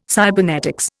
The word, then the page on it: cybernetics: Indian